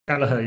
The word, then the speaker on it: Kelleher: Yeah, so Keller is it pronounced Keller? Kelleher? – Kelleher, yeah.